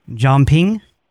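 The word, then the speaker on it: Jeomping: John Ping. – Is that J-E-O? – Yeah, can you spell it for me?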